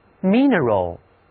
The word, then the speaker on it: mineral: 这回啊，字母 I 读音发生了变化，读错短一 mineral，这个词既是形容词矿物的，又可以做名词表示矿石矿物。